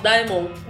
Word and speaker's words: Daemon: É, na verdade, Diamond, Demon, como é que se pronuncia? A gente costuma falar Diamond.